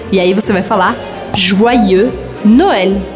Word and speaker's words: Joyeux: Essa palavra se escreve com Y e o Y eu considero como se fossem dois Is. Então o primeiro I vai fazer o som Ua, Joa, e o segundo I eu vou pronunciar Joyeux, Joyeux Noel.